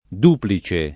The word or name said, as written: duplice